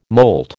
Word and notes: molt: American English

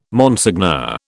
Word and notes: Monseigneur: British English

